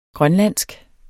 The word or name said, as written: grønlandsk